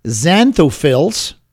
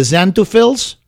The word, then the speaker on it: xanthophylls: The xanthophylls, a tough word, xanthophylls is spelled X-A-N-T-H-O-P-H-Y-L-L-S. X-A-N-T-H-O-P-H-Y-L-L-S.